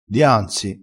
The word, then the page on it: dianzi: dialettale